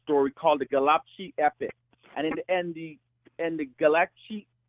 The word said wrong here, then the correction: Gilgamesh: no, let me say it properly. – Yeah, Gilgamesh.